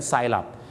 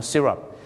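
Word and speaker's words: syrup: ไซรัปแต่ถ้าคุยกับฝรั่งนะครับถ้าเป็นไปได้ก็ออกเสียงว่าซีรัป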